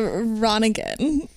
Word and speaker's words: Ronningen: It's a Ronningen. – Ronningen. – Now, we're saying Ronningen, though... There is an unpronounced N in there. – Yeah, it's an N-heavy last name. – How do you spell it? – R-O-N-N-I-N-G-E-N.